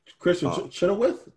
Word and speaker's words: Chenoweth: Kristen Cohenworth. Charles Edwards. – Kristen Chenoweth? – Yeah, thank you. I always butcher her name for some reason.